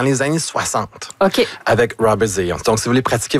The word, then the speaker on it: Zajonc: Vous avez dit plus Zajonc, mais... – Ah, parce que j'ai prononcé un petit peu à l'anglais. J'ai prononcé mon polonais à l'anglais.